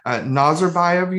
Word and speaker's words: Nazarbayev: Nazarbayev University? – Yeah, that's right.